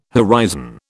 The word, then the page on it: horizon: British English